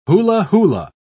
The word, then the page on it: hula-hula: British English